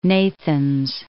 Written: Nathans

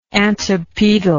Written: antipedal

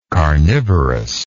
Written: carnivorousness

